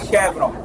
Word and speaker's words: Chevron: A pronúncia que se fala aqui no mercado é Chevron.